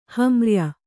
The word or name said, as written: ಹಮ್ರ್ಯ